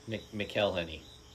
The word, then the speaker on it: McElhenney: I can't ever say his name right. Rob McKinley. – McElhenney. – I think you're right.